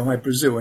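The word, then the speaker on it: Hy-Brasil: High Brazil, people pronounce it High Brazil, and it's Brazil, okay. – Yeah, yeah. But it's High Brazil because that's how the Irish would pronounce it, because it's an, okay, it's an island that's off the southwest coast of Ireland.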